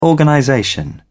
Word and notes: organization: British English

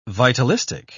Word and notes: vitalistic: British English

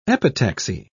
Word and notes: epitaxy: British English

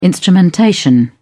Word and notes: instrumentation: British English

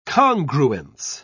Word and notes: congruence: British English

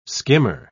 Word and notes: skimmer: British English

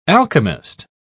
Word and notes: alchemist: British English

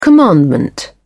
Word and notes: commandment: British English

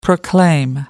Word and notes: proclaim: American English

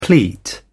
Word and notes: pleat: British English

